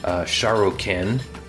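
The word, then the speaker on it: Sharrukin: And if we're speaking his language, Akkadian, we would pronounce his name Sharokin, which means true or legitimate king.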